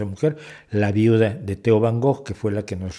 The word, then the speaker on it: Van Gogh: Entonces, eh, de mucho de lo que hizo Van Gogh, Van Gogh, para ser correctos en la pronunciación,